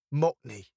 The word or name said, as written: mockney